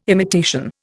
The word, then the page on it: imitation: Indian